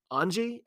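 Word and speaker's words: Ange: And obviously, I don't think I've ever heard his first name pronounced. They say Capuzzo over and over, but it's Angie.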